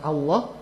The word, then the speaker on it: Allah: Karena Allah jadi ditebalkan. Allah. Sodako Allah. Apa? Takob bala loh. Kenapa Allah? Karena diawali oleh harokah fathah.